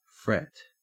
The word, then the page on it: fret: Australian English